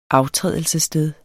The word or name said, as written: aftrædelsessted